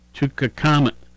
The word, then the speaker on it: Tutankhamun: 1923, Howard Carter opens the inner burial chamber of Egyptian pharaoh Tutankhamun, Tutankhamun's, Tutankhamun's. I think I said it right the first and third time.